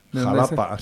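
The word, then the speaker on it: Xalapa: En Veracruz, en el estado de Veracruz, está Xatapa, me parece. – Jalapa. – Jalapa, se dice con X, sí. – Jalapa, que se escribe con X.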